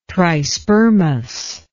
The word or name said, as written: trispermous